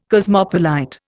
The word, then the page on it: cosmopolite: Indian